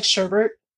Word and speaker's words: sherbet: It's kind of like sherbet. There's no R in it, but every Midwesterner puts the R in, so it's sherbet.